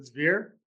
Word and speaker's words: Zveare: it's Eitan Zviri. – I don't know him. I think that's how you pronounce it, Zvir. – Zvir, okay.